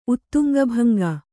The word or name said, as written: ಉತ್ತುಂಗಭಂಗ